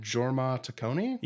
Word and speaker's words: Jorma Taccone: Yeah, and Jorma Taccone.